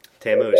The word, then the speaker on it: Tammuz: Tammuz. – That's how you pronounce it. You got it.